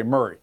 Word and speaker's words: Maury: And now keep in mind, in Tennessee, it's spelled Maury, but we say Murray.